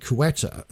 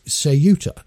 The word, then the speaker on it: Ceuta: I was pronouncing it Quetta. And that's because I'd watched news reports on it by the BBC and by GB News. And they were all referring to it as Quetta. But apparently it is Ceuta, the way that it is actually pronounced.